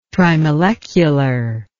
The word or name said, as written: trimolecular